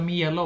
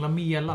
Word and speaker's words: Lamela: Lamela också, inte Lamela, mm. utan Lamela.